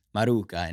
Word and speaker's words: Maruca: Matt Maruca? Is that how you say it? – Yeah. – All right. All right. – Maruca in Italian.